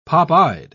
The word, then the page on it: popeyed: British English